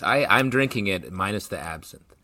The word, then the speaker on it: absinthe: Based on your book, it is rye whiskey, amaro, honey syrup, lemon juice, absinthe, Angostura bitters. Am I mispronouncing that? – No, that's right.